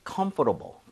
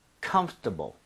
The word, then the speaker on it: comfortable: 원어민들이 comfortable, comfortable 이렇게 부드럽게 하는 사람도 있는데요. – 네. – 오히려 이 단어는요. 이런 발음을 들을 확률이 높아요. 뭐냐면 comfortable, comfortable. 뭐냐면